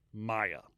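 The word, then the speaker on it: Meagher: At least that's how ChatGPT told me how to pronounce it, because I had to ask it because nothing else would tell me. M-I-A-G-H. Maya. It's an Irish name.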